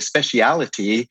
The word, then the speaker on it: speciality: And the specialty, or as the British say, the speciality of Sardinia is this baby pig on a spit over oven fire.